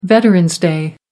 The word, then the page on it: veterans day: American English